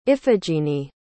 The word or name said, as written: iphigenie